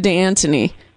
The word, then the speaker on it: D'Antoni: D'Antoni or D'Antoni? – D'Antoni.